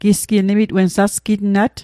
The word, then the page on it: sasqitnat: Mi'gmaq